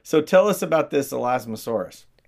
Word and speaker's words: Elasmosaurus: So now you have this idea that maybe Nessie is an Elasmosaurus. Did I say it right that time? – Yeah, Okay, good. – you said it good.